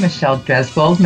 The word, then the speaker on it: Michelle Dresbold: I have a very special guest this morning, Michelle Dresbold. Michelle, am I pronouncing your name right? Yes, you are.